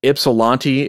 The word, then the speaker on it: Ypsilanti: And uh, if anybody out there is trying to look this stuff up or Google it or whatever, um, I had to look up how to pronounce this actually, but um, Ypsilanti is spelled Y P S I L A N T I.